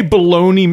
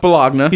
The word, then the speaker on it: bologna: When he comes back, he sees Steve there and he's like, hey, baloney man. – Say it right. It's pronounced Bologna.